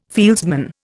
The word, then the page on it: fieldsman: Indian